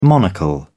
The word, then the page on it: monocle: British English